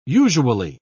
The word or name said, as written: usually